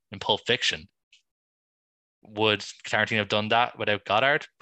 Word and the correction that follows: Godard: Oh, sorry, I should be saying Godard, not Goddard. We don't pronounce the D.